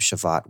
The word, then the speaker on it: Shabbat: It's a B when you write in English, but it's more of a V pronunciation. – Mm, okay. So Shabbat.